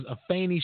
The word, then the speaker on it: Afeni: And yes, I said Afeni because her name was not Afeni. It wasn't Afeni or Afene. It was Afeni Shakur is the correct way to pronounce her name.